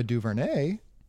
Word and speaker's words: DuVernay: How do you say that? – I believe it's Ava DuVernay. – Ava DuVernay.